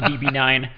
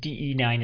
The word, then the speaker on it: DE9: In fact, I looked it up just before this show so that I could say it correctly, because I kind of knew we've all been saying it wrong, but I wasn't sure the exact details, so I looked it up, and DE9 is in fact correct.